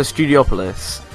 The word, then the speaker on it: Studiopolis: So apparently it's Studiopolis. So Studiopolis. Which I never know how to say it, but apparently it's Studiopolis according to the team.